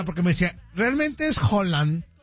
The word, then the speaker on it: Haaland: Ya, pero por dentro me dicen, Haaland.